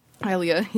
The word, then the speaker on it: Hylia: Maybe do like a lake house on Lake Hilly, Hylia, yeah. I always said it wrong as a kid.